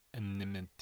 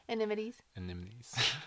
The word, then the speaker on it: anemones: All the anemones. How do you say that word? – Anemones. Not anemones. – Anemones.